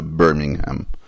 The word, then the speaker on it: ברמינגהם: הסיפור הזה נקרא הסיפור על ג'ון מברגינהם, סיפור, או איך בדיוק מבטאים את זה? מבטאים את זה ברמינגהם, שזה עיר באנגליה.